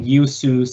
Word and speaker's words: Yeosu: and Yeosu, South Korea complexes. My Korean's a little rusty. Hopefully I pronounced those cities correctly. – I think you did pretty well.